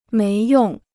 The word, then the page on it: 没用: méi yòng